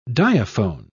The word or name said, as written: diaphone